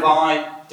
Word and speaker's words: Ai: It's pronounced Ai, it's A-I.